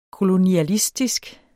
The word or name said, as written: kolonialistisk